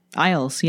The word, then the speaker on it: Isles: So the archipelago of Orkney, also known as the Orkney Isles. Is that how you say it? – Isles, yeah.